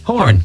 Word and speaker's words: Horan: I've seen Harry Styles and Niall Horan. Is it Horan or am I pronouncing his surname correctly? – Horan.